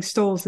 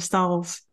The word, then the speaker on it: Stahls: Even saying Stahls wrong. I'm saying Stahls instead of Stahls.